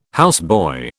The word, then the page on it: houseboy: British English